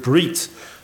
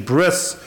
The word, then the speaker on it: brit: In fact, to such a degree that when we say the word brit, or we say the word in Ashkenazic Yiddish pronunciation, bris, we immediately assume the action of brit milah, the action of circumcision.